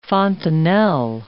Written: fontanelle

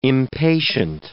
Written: impatient

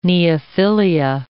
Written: neophilia

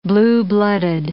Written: blue-blooded

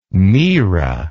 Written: myrrha

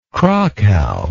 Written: crakow

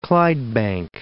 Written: Clydebank

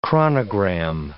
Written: chronogram

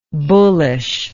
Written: bullishness

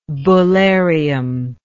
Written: bullarium

